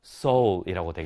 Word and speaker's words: Seoul: 그래서 soul이라고 되겠죠. 아, 그대로 이걸 그대로 읽으면 soul이라고 발음이 나겠죠.